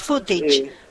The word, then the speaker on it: footage: Sí, yo busqué la pronunciación y es como lo estás diciendo vos, es footage.